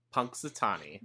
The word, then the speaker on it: Punxsutawney: It felt a little bit more like Palm Springs, where he kind of woke up already in Pensatucky. Did I say that right? – Punxsutawney, thank you.